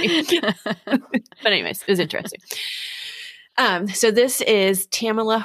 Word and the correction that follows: Tamla: Horsford's, not Tamala, I'm sorry, Tamla. Is how you say Tamla.